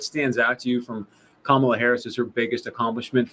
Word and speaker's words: Kamala: Kamala Harris, yes.